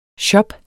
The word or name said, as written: shopper